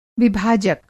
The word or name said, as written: विभाजक